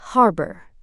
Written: harbour

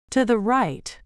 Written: to the right

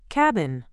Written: cabin

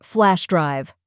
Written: flash drive

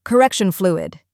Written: correction fluid

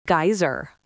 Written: geyser

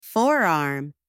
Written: forearm

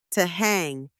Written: to hang

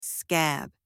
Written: scab

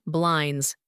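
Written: blinds

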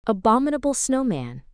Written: Abominable Snowman

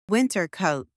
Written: winter coat

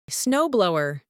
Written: snow blower

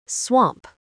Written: swamp

